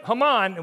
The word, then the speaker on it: Haman: So every time Haman, which is how you pronounce his name, I said Haman today.